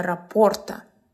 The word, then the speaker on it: рапорт: Сегодня важна нужная тема – установление рапорта. Что такое рапорт? Это связь, доверие. Термин французский, поэтому ударение на последний слог.